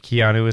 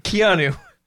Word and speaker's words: Keanu: Like the U isn't like, it's not super pronounced. It's Keanu. – I don't think anyone pronounces it that way. – Keanu Reeves?